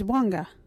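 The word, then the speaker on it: Wangga: Our local Wadjuri word is Wanga.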